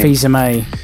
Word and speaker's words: Fils-Aimé: Fils-Aimé.